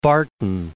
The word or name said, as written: Barton